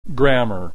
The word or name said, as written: grammar